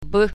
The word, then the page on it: b: latvian